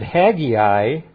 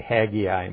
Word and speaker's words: Haggai: When I say Haggai, most people pronounce that Haggai, don't they? But when I was a beginning believer, the guy I used to go to said Haggai and Sinai, and I find myself saying it that way too.